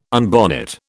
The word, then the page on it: unbonnet: British English